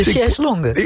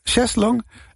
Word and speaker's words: chaise longue: De Cheslongen. – Ja, nou, ik probeerde de allerbeste Frans maakt er iets longues. Ik maak er iets heel raars van. Het is een chaise longue. – De chaise longues, ja, dan moet ik ook even twijfelen.